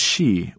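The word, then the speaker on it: shi (四): In Japanese, the number 4 is pronounced shi, which means death.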